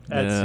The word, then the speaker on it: Etsy: She works at the Estee shop. – Etsy. – Is that how you say it? – Etsy.